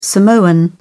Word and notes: samoan: British English

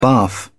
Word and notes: barf: British English

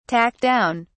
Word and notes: talk down: British English

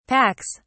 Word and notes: packs: British English

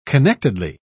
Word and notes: connectedly: British English

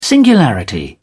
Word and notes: singularity: British English

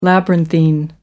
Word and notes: labyrinthine: American English

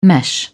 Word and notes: mesh: British English

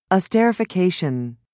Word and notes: esterification: British English

